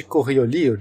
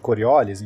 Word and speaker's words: Coriolis: força de Coriolis, de, de Coriolis, enfim, depende como você quiser pronunciar, que ela é muito esquisita, ela surge nesses referenciais não iniciais e ela vai meio que te jogar pro lado.